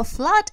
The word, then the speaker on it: flood: Вообще слово flood – это наводнение. Там пишутся две буковки O, а произносятся они как a. «а». Flood.